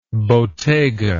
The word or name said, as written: bottega